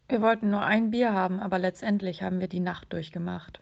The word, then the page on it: die Nacht durchmachen: German